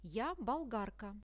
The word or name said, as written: ja bolgarka